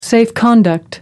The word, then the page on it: safe conduct: American English